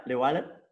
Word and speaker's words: Llewellyn: Where, if I please it, correct me if I'm pronounced it, Charlie Llewellyn. – Yeah, Llewellyn.